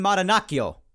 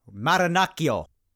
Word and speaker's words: Marinaccio: That's how I believe that's how you pronounce that name, and looking at it as someone who speaks a decent amount of Italian, that's how it should be pronounced if it's not, so Ron Marinaccio. Gonna say with the roll of the R and everything, gotta have that rrrr, Marinaccio.